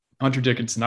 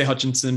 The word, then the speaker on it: Dickinson: Hunter Dickinson. I wouldn't say Hutchinson, but Hunter Dickinson.